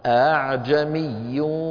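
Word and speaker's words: أأعجمي: لكن حفصا رحمه الله شيخنا في السند رواها عن شيخه عاصم بتسهيل الهمزة الثانية هكذا أعجمي وعربي. التسهيل هو أن تنطق الهمزة بدون قفل الوترين الصوتيين. علماءنا القدامى كانوا يعبرون عن ذلك بقولهم التسهيل هو النطق بالهمزة المسهلة بين الهمزة المحققة وحرف المد المجانس لحركتها. إيش حركة الهمزة؟ فتحة. إيش يناسب الفتحة من حروف المد؟ الألف. إذا ننطقها بين الهمزة والألف.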